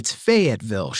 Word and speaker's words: Fayetteville: Amy Prince checking in on Facebook and correcting my pronunciation of Fayetteville. It's Fayetteville, she says. Fay, capital F-A-Y, Etville, Fayetteville, Arkansas.